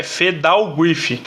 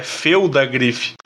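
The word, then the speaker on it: Phelddagrif: E eu sempre falava Fedalgriff. E o L é antes do D, né? Então é Feudagriff. – Feudagriff. – Eu sempre pronunciei errado o nome dessa carta.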